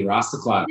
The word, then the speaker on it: Rastaclat: You got it perfectly, Rastaclat.